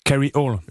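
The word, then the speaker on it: Kryole: Vous êtes le cofondateur de Carriole, ça s'écrit K, trait d'union, R, Y, O, L, E. Rassurez-nous, c'est plus simple à utiliser qu'à écrire. – Oui, tout à fait. On peut même le prononcer en anglais carry all.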